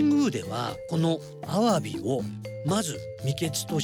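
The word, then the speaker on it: 鮑: 魚辺に包むと書いてアワビと日本語では読みます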